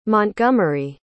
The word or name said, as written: montgomery